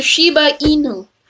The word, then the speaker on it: Shiba Inu: It's the Shiba Inu.